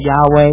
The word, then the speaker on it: Yahweh: However, I would submit that Yahweh is actually the better pronunciation because it's the Hebrew pronunciation.